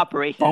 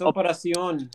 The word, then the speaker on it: Operación: And I've been realizing, right, that we've been saying it wrong. It's not operation. – Boners. – It's operacion. – Operacion, you know, boners. – The Spanish term for operation.